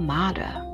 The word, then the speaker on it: mater: Mother is the modern English equivalent of an old English modor, M-O-D-O-R, pronounced mater. This comes from the Latin word mater, M-A-T-E-R, pronounced mater, mater. Okay, pronounced mater, M-A-H-T-E-R, that's how you pronounce it.